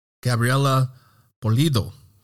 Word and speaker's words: Gabriela Pulido: Gabriela Pulido.